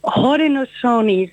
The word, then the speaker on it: Haudenosaunee: De inheemse makers, ja, ik heb er wat van gemaakt, maar hoe spreek je dat uit? – Haudenosaunee, zeg je.